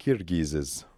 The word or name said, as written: Kirghizes